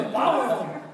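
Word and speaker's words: bodhrán: Okay, so okay, but legit, it was on the drum, and I was like, "That's a Bodrum, right?" – No, it's a Bowron. – Bowron. – Bowron.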